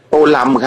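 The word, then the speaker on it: Tô Lâm: ค่ะถามเรื่องการออกเสียงชื่อท่านหน่อยได้ไหมคะอ่านว่าโตเลิมหรือโตลำคะโตลำครับผมอยู่ที่เวียดนามเรียกท่านโตลำครับ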